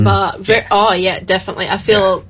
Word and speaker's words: La Bamba: And we played La Bamba, if I'm saying it correctly. – Yes, you are saying it correct. Perfect. – La Bamba.